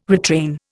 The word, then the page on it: retrain: Indian